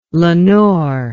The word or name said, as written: lenore